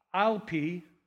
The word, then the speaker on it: Ielpi: It's Lee Ielpi, I-E-L-P-I.